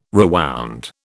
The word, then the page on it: rewound: British English